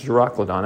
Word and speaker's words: Euroclydon: So they took the word in the Greek, it's Euroclydon, that's how you pronounce it, and it means wind and wave.